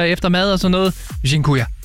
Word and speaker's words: Dziękuję: Og en af dem, som jeg har brugt rigtig meget, det er det, der hedder, undskyld min udtale, Dziękuję. – Dziękuję? – Dziękuję.